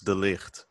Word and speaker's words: de Ligt: But outside of him, outside of uh, Matthias Delich, I'm sorry. How can you help me out with the pronunciation here? – but, but of course, it's Matthijs de Ligt.